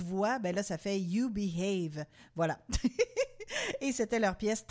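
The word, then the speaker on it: Eubie Hayve: C'est très drôle parce que la façon dont c'est écrit, faut vraiment le prononcer pour deviner que c'est You Behave. Mais tu sais, ça s'écrit E-U-B-I-E. Après ça, un autre mot, H-A-Y-V-E.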